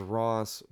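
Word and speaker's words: Rauce: So the best way I could tell you is that my name is Ross, but I spell it like sauce.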